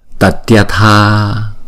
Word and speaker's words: tatyatha: Thì á, chữ này thì chúng ta đọc là tatyatha, giống như là tatdia dia đó, tatdia tha Tatyatha, mà chúng ta đọc rất là nhanh là Tatyatha, Tatyatha, Tatyatha.